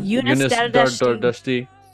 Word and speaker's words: Yunus Dardashti: Yunus, right? Is that how you pronounce his name? – Yunus Dardashti.